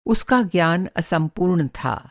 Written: असंपूर्ण

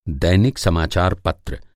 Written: समाचार-पत्र

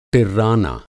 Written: टिर्राना

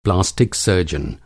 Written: सर्जन

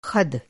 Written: ख़द

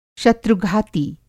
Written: शत्रु-घाती

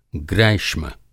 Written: ग्रैष्म

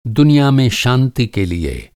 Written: शांति

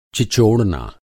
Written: चिचोड़ना

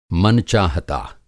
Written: मन-चाहता

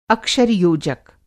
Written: अक्षर-योजक

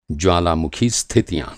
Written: ज्वालामुखी

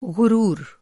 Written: ग़ुरूर

